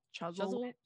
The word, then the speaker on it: Chuzzlewit: Chuzzlewit.